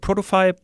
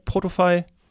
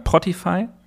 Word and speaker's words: Protofy: Spreche ich es eigentlich richtig aus? Würdest du es auch so sagen? Protofy, Protofy? Genauso, genauso würde ich es auch aussprechen.